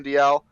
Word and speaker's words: Dalziel: His name is spelled Ryan Dalziel, but like I think it's Ryan Dial. Yeah. Ryan Dial.